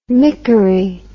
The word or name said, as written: mickery